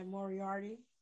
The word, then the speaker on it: Moriarty: I cannot pronounce his last name. – Yeah, Moriarty.